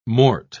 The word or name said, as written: mort